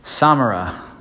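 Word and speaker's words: Sámara: S-A-M-A-R-A. Accent on the first A. We weren't sure because Nosara is right up the road. So was it Samara? Feel pretty confident at the moment that it's Samara.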